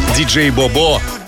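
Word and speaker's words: DJ BoBo: Диджей Бобо. Вот именно так произносится имя этого исполнителя.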